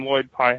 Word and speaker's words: Lloyd Pye: One of the things I've noticed lately, not necessarily when Lloyd Pye had it. Lloyd Pye, did I say that right? – Yeah, you said it right.